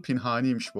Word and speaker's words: Pinhani: Pinhani'nin doğru telaffuzu Pinhani'ymiş bu arada. Bu konuda grup da çok hassasmış.